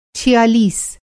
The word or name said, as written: छियालीस